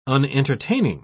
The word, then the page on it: unentertaining: British English